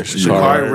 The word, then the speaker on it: Sha'Carri: Yeah, Sha'Carri Richardson, Olympic athlete who was suspended by the, what's the name, for Olympics for smoking weed.